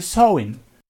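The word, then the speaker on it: Samhain: Eh, se, escribe Samine, Samine, pero se pronuncia sewing.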